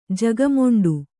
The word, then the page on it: ಜಗಮೊಂಡಿ: jaga moṇḍi